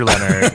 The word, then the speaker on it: Leonard: Is it Andrew Leonard? Is it like a fancy way that you say it? – No, just Andrew Leonard.